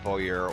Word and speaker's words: bowyer: A bowyer? – A bowyer is how we call them? – And I believe that's how you pronounce it. – Yes. – Bower.